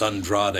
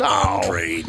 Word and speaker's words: Andrade: It's Andrade. It's not Andrade. – It's Andrade.